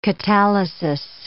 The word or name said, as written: catalysis